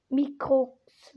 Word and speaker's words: Microx: Und jetzt noch die zweite Info und zwar an Mikros Gaming Podcast. Ich glaube, der Name wird so ausgesprochen. Irgendwie guckt nochmal jetzt auf Spotify. Mikrox, Mikrox Gaming Podcast, ja.